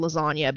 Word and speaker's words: lasagna: Later on, I became obsessed with the word lasagna because the G is silent.